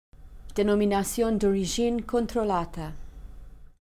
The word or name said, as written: Denominazione d Origine Controllata